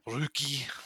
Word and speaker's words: Roki: R-O-K-I. – Okay. But it's pronounced. – I think it's Finnish, so it's like Ruki. – Okay. – I'm sure that's even not even close to the actual pronunciation. Ruki. – That's closer.